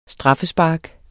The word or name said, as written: straffespark